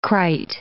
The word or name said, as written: krait